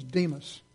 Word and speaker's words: Demas: His name was Demas. Rhymes with demon.